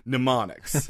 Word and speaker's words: mnemonics: I'm just putting it too much of an M at the beginning. – Yeah. – Mnemonics.